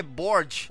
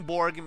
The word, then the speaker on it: Borg: Só que na Noruega e na Finlândia, não, na Noruega e na Dinamarca, a pronúncia é mais parecida com Borg mesmo, mas o significado é o mesmo, castelo.